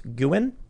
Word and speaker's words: Nguyen: Also, Nguyen is pronounced Guin. I've been told it's pronounced like three different ways, like Win, Nguyen, Nguyen.